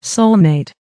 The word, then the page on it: soulmate: American English